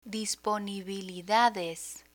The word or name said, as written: disponibilidades